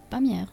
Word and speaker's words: Pamiers: Fauré nació en 1845 en una ciudad al sur, cerca de España, que se llama, yo la leo y vos me decís cómo se pronuncia, Pamiers. – Pamiers. – Ahí está, de acuerdo.